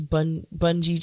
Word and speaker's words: bungee: Is it bungee jumping or bungee jumping? Because now I'm really getting annoyed that I can't pronounce it. – Bungee. – Oh, it's called bungee jumping.